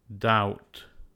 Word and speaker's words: doubt: With silent letter B. There are only 2 common words ending in BT, debt and doubt.